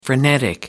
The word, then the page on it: frenetic: American English